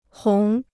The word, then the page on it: 红: hóng